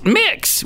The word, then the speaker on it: Mx: And how do you say the MX? Is it Mix? – I think it's Mix, because she put up there, you know, the how to pronounce it, like Mix.